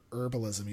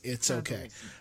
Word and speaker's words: herbalism: I call them herbalism. You don't pronounce the H, but some people do pronounce the H. It's herbalism.